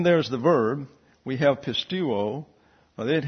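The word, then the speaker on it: pistuo (πιστεύω): The EU is a diphthong and gets a long U sound. So it's pistuo.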